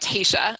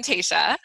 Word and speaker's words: Taesha: Sure, I'm I'm Taesha.